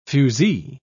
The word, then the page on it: fusee: British English